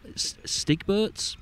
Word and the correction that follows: Stigbergets: Yeah. – Swedish, I think. – Yeah. Stigbergets.